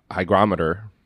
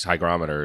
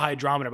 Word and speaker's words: hygrometer: I think his hygrometer is probably not calibrated. – It's probably I'm guessing it's an analog hydrometer, which is... – Hydrometer or hygrometer? – I call it a hydrometer, but it's spelled hygrometer.